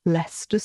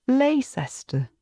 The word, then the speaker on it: Leicester: how about Leicester Square, spelt L-E-I-C-E-S-T-E-R. You might think it was Leicester Square or Leicester Square. Similarly, Gloucester Road, spelt G-L-O-U-C-E-S-T-E-R.